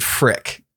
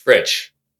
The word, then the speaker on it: Frich: So is it Fritch or is it Frick? F-R-I-C-H? – It's Fritch. – Fritch. Okay. Yeah.